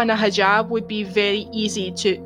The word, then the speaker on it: hijab: is that how you pronounce it? Uh, yeah, hijab, uh, burqa.